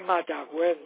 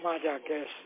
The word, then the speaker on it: Mayagüez: Es Mayagüez, no Mayagüez. – Ok, gracias. – Mayagüez, ok, gracias. – Aún si lleva los dos puntitos, se pronuncia como Mayagüez, como juez.